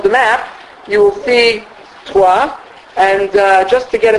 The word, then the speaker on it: Troyes: Troyes. – See, I can't pronounce it. That's why I asked you to say it. T-R-O-Y-E-S, right? Troyes, I think it's pronounced.